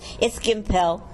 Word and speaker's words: Gimpel: No. It's Gimpel. – Gimpel. Oh, you put the French thing on.